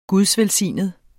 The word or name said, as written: gudsvelsignet